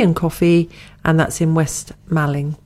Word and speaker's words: West Malling: It's £10 for lunch, tea and coffee, and that's the Spitfire Pub, and that's in West Malling. – Yeah. – Have I said it right? – West Malling, yeah. Absolutely.